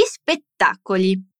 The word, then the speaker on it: spettacoli: Gli spettacoli. La frase era Mi piacciono gli spettacoli. Dilla con me e fai attenzione alla pronuncia della parola spettacolo con la doppia T.